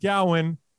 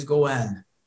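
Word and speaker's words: Gowan: And if you want to pronounce it Gowen, it's okay with Gowen, I'm sorry. You know what's funny? I wrote Gowen and then I said Gowen because of the Gowanus in Brooklyn. I keep saying Gowen and it's Gowen.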